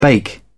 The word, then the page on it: bake: British English